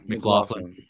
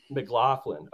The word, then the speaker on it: McLaughlin: But I can never pronounce the running back's name. Jaleel McLaughlin. – McLaughlin. Okay, that's how you say it.